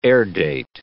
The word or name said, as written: airdate